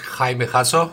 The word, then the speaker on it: Jaime Jasso: Yes, my name is Jaime Jasso.